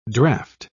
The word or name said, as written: draught